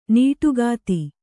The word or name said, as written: ನೋಟಗಾತಿ